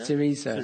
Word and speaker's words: Syriza: How do you say this, darling? Is it Syriza? Syriza? – Syriza.